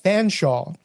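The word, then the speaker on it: Featherstonehaugh: They're sounded as Chumley and Fanshawe.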